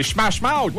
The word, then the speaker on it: Smash Mouth: C'est Smash Mouth.